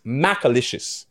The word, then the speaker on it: Macalicious: Okay, because I might be pronouncing it wrong, but it is Mac, like Max, Macalicious.